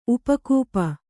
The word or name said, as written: ಉಪಕೂಪ